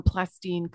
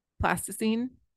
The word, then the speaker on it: plasticine: is created from plastine clay. Did I say that right? – Plasticine? – Plasticine.